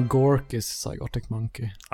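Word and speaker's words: Gorky's Zygotic Mynci: som heter så mycket som Gorkys Zygotic Monkey. – Oj, eh, eloge för vad jag tror är ett korrekt uttal. – Ja, Gorkys Zygotic Monkey.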